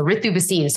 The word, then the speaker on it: Ritu Bhasin: And so I think, but I go by the Indian pronunciation, so Ritu Bhasin.